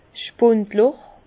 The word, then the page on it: 's Spundloch: Alsatian; Bas-Rhin; Herrlisheim